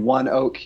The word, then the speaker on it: ONEOK: and One Oak Inc. It spells One O N E O K. It's pronounced One Oak uh, Inc.